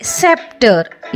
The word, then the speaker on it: sceptre: SCEPTRE, ಸೆಪ್ಟರ್. ಇಲ್ಲಿ C ಇದ್ರೂ ಅದು ಸೈಲೆಂಟ್ ಆಗಿದೆ.